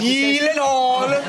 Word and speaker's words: Gyllenhaal: So shouldn't it be like Gyllenhaal? – Oh my god. – Gyllenhaal. – The only two places that is pronounced correctly, my last name like you did just now, is in Sweden and in IKEA.